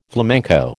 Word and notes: flamenco: American English

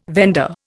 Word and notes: vendor: Indian

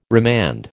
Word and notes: remand: American English

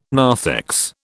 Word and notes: narthex: British English

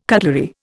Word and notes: cutlery: Indian

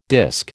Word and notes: disc: American English

